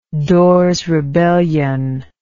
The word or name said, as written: dorrs rebellion